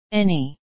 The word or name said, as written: any